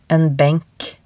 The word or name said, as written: en benk